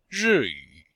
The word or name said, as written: ri4yu3